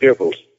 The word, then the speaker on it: Goebbels: We don't have a controlled press like you did with Joseph Goebbels, and that's how it's pronounced, Goebbels.